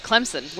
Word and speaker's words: Clemson: Clemson with an S. Yeah. – Yeah. I can tell you that's not how people are going to say it around here. – That's all right. – Clemson, Towson, Bison.